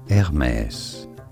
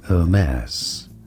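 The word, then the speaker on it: Hermès: In French, it is said as Hermes. Hermes. It'd be fine in English to say it as Hermes. Hermes. And now you know.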